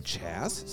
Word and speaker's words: Chaz: Since we're here already off the rails and fact-checking stuff, Perplexity says, Roger Ebert's wife, which I think you say her name, Chaz, C-H-A-Z, recounted his final words in a letter.